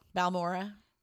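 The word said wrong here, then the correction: Balmorhea: Balmoray. Mm-hmm. That's how you say it.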